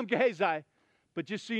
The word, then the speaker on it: Gehazi: She's going to encounter Gehazi, which, by the way, I am totally butchering that guy's name. If you've heard it pronounced a different way, it's totally reasonable. If you pronounce it in Hebrew, it's Gehazi.